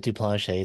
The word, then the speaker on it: Duplantier: Duplantier. Did I say that right? I feel. – Duplanchet. He pronounced it Duplanchet. It's a French name, so he That's what I thought.